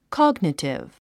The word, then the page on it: cognitive: kάɡnətiv; コグニティブ